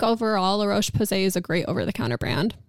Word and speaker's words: La Roche-Posay: La Roche-Posay, if that's there. That's probably what I would go for. Is that how you say it? La Roche-Posay. Yeah, that is. I heard La Roche-Posay.